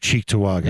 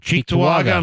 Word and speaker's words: Cheektowaga: Well, I'm saying most people have never heard of Cheektowaga. – Yes. – Okay. Or Lackawanna. – And you pronounced it correctly.